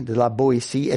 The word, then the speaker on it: de la Boétie: And I realized that I should have written this down on the handout, but it's spelled D-E-L-A-B-O-E-T-I-E, de la Boétie, Étienne de la Boétie, Étienne, E-T-I-E-N-N-E, Étienne de la Boétie.